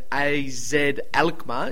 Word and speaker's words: AZ Alkmaar: So the current top two in Ajax and AZ Alkmaar. Is that how you say it, Mark? – Yeah, you've done well, mate.